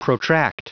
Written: protract

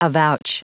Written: avouch

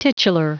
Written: titular

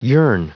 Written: yearn

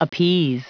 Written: appease

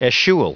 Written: eschewal

